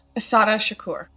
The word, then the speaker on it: Assata Shakur: Asada Shakur.